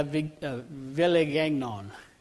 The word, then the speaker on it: Villegagnon: There was a French knight by the name of Nicolas Durand de la Villegagnon. Now, I know I haven't said that right, but it's V-I-L-L-E-G-A-G-N-O-N.